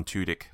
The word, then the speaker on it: Tudyk: I think you were talking about Alan Tudyk being the voice of the robot. – That's how you say it. – Yeah. – I was saying it a slightly different way, which the emphasis was slightly different and gave a completely different.